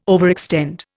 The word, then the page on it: overextend: Indian